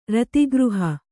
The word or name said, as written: ರತಿಗೃಹ